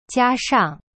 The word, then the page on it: 加上: jia shang